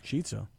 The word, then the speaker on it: Chizza: Chizza, like spelled like pizza, but pronounced chizza, like pizza, features two white meat extra crispy fried chicken fillets as the base, topped with zesty marinara sauce, mozzarella cheese, and crispy pepperoni.